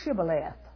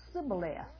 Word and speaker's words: Shibboleth: And if he said no, then he would say to them, well, say now, Shibboleth. But if he said Sibboleth, Notice the difference? Shibboleth. But he couldn't say Shibboleth. He would say Sibboleth.